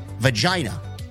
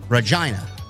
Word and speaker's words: Regina: As a Canadian, you got the pronunciation of Regina correctly. No need to apologize.